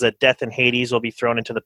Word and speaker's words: Hades: Did you say Hades? You're doing the original pronunciation or something? Is that what you're doing here? – Yeah, Hades. Hades, the Greek, Gehenna, the Hebrew, which are somewhat different, but they have some similarities, correct?